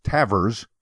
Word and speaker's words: Tavres: Because his name is pronounced Tavers, his last name, but it's spelled T-A-V-R-E-S.